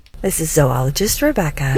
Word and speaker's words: zoologist: It's pronounced zoologist, not zoologist.